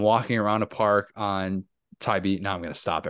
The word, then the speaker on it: Tybee: Tybee.